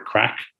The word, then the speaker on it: craic: And I did find then there was a debate with the origin of the word crack, C-R-A-I-C, as it's spelt in Irish.